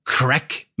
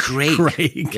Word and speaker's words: Craig: Und dann gehen wir aber sozusagen auf eine weitere Spur und es geht jetzt um Crack McLean. Ich habe da immer gedacht, der heißt wirklich Crack, wie die Droge mit Vornamen. Aber es ist natürlich Craig.